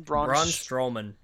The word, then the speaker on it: Braun Strowman: Braun Strowman.